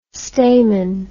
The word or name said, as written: stamin